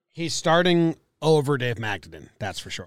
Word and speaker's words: Magadan: Say it quick and fun, like it's a nut. – Magadan. – Close. Magadan.